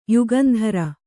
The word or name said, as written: ಯುಗಂಧರ